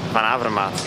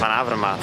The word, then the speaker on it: Van Avermaet: Hey, Whitey, uh, I heard you have some trouble with pronouncing my name. It's Greg Van Avermaet. Van Avermaat. Van Avermaat.